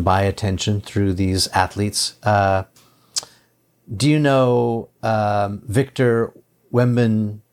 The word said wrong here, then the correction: Wembanyama: Let's see, Wembin. What's his name? Victor Wembanyama. Yeah, Victor Wembanyama.